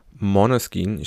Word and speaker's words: Måneskin: Måneskin, ich habe so gelernt, spricht man es aus.